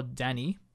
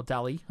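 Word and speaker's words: Dalí: Dalliland is a biographical drama with Ben Kingsley starring as a surrealist painter, Salvador Dali. Or Dali, I should say.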